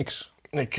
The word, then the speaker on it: Xi: Xi spreek je niet uit als Xi, hè? – Nou.